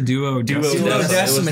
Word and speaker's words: Duodecim: Duodecim is how it's pronounced, but it's 012 in, was it braces or...